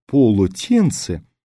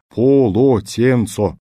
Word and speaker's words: полотенце: Слово, которое на письме выглядит как «полотенце», вы произносите довольно неясно, как «полотенц». Более того, попробуйте выговаривать его в точности, как оно изображается буквами на бумаге. «Полотенце». Вам сделают замечание. Так произносить это слово не принято.